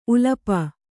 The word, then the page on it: ಉಲಪ: ulapa